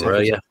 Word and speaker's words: Broja: I think we've been told it's Broja. We were told in the summer, because obviously we spoke about him a lot on Sky in January. We were told 100% it's Broja.